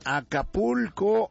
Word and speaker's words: Acapulco: ¿Acapuzco o cómo? – En Acapulco, en Acapuzco.